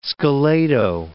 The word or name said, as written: scalado